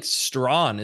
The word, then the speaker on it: Strawn: And it's pronounced like Strawn, isn't it? – Strawn, yep.